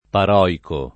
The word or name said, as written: paroico